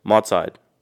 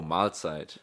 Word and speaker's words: Mahlzeit: Mahlzeit. – Oh, Mahlzeit. – Mahlzeit.